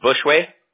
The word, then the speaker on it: Bushway: Just like it sounds, Bushway? – Yep, B-U-S-H-W-A-Y.